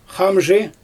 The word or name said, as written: xamʒɯj